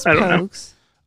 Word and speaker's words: Pogues: Could it be, is it pronounced Pogues or Pogs? – It might be Pogues. – It was Pogues.